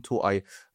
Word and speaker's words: Toei: Vielleicht heißt es ja Toei Animation. – Es ist Toei.